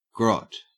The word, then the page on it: grot: Australian English